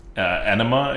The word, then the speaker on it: Anima: Anima Extractor. A-N-I-M-A. Anima.